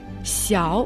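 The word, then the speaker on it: 小: «Сяо» в третьем тоне.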